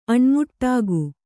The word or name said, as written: ಅಣ್ಮುಟ್ಟಾಗು